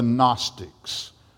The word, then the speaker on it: Gnostics: There was a group within the church, in the early church, called the Gnostics, spelled with a silent G.